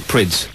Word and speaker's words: Prydz: Now, I've been told it's actually not Eric Prydz, as most people are saying, but it's actually Eric Prydz. Is that right? – Yeah, that's the correct pronunciation. – So Prydz. For weeks on Radio 1, we've all been saying Eric Prydz, and actually we got it wrong. It's Eric Prydz.